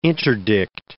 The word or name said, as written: interdict